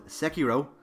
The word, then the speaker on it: Sekiro: I chose Sekiro, That's how I'm pronouncing Sekiro.